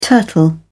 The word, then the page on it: turtle: British English